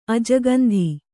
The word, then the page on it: ಅಜಗಂಧಿ: ajagandhi